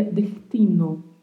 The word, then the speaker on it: destino: E outra particularidade que pode haver em algumas regiões é de essa letra S ser mais suavizada, não ser pronunciada tão marcada assim, destino mas ela ter um som como se fosse um som de um R bem, bem, bem suave. Ficaria dertino, dertino. Em alguns lugares a gente ouve essa pronúncia.